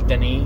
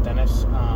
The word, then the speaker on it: Denis: I always want to pronounce it as if it was Spanish, but I know he's French, and I know his first name is Denis, not Dennis.